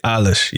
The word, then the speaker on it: Eilish: Billy Eilish, yes.